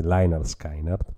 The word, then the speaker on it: Lynyrd Skynyrd: Io purtroppo opto per la pronuncia All American Boys, quindi Leinard Skynard, con Double Trouble.